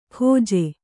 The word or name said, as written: ಖೊಜ್ಜೆ